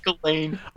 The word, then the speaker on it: Ghislaine: and Gasline Ghislaine Maxwell, who had reported both the Gasline. – Yeah, Ghislaine. – Ghislaine. It's pronounced Ghislaine.